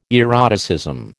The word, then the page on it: eroticism: American English